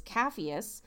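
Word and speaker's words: Capheus: Which took me forever to learn to pronounce the name. It's Caphias.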